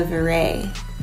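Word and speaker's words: Viray: Oh, my Instagram is @ Pamela Veray. – There you go. – Just the way it sounds, @ Pamela. But last name is spelled V as in Victor, I-R-A-Y as in yellow. – And you can always hit mine up @ Sean Pierre. That's S-E-A-N _P-I-E-R-R-E _.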